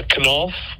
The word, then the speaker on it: Knauf: It actually sold in 2019 to a Knauf German company. It's spelled K-N-A-U-F.